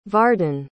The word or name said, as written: vardan